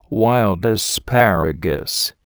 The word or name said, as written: wild asparagus